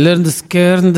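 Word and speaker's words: Lynyrd Skynyrd: Eccoci ancora in studio, arriviamo all'ultimissima parte della di questa puntata zero di Flying on Little Wing con il Learned Skirnd, la cui pronuncia sezzese ci è stata suggerita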